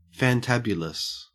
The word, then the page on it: fantabulous: Australian English